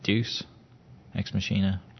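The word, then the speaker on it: Deus Ex Machina: one of my like kind of favorite brands to just keep an eye on is, and I always say it wrong, is it Deuce Ex Machina? You say it right? – Yeah.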